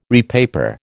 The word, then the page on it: repaper: American English